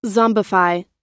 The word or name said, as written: zombify